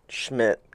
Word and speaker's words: Schmitt: I mean, my name kind of, Schmitt kind of rhymes with kind of a swear word.